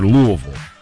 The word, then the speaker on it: Louisville: Do you say Louisville or Louisville? – I think they say it Louisville. – Louisville. – So you got to say it like that or you sound like a Yankee.